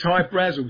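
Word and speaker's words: Hy-Brasil: yeah, but it's High Brazil because that's how the Irish would pronounce it, because it's an okay.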